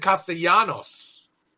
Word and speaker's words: Castellanos: And you said Castellanos. That's how you say your name? – So, it's funny. So, basically, because in Spanish, you know, the two L's. – Castellanos, yes. – Castellanos. Yes. I say Castellanos.